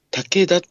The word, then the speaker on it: 竹田: この平東県の中にある竹田っていうところがあるんですよ。竹の田んぼと書いてね、竹田。